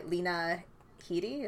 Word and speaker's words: Lena Headey: Lena Headey. Is that how you say her name? – Yeah, that's right.